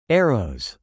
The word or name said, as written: arrows